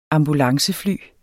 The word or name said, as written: ambulancefly